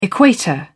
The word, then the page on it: equator: British English